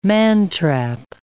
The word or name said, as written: mantrap